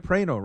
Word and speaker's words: Praino: Praino, right? – Praino, yeah.